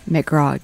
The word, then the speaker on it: McGraw: McGraw.